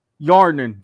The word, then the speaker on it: Hjarnen: Hjarnen, the H is silent.